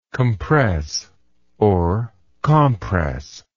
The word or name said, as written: compressingly